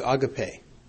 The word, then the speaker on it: agape: And so the Greek word used here is agape, A-G-A-P-E, or as we perhaps pronounced it in the past, agape.